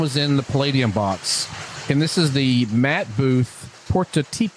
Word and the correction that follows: Prototipo: I gotta say this right, Prototipo. – Prototipo.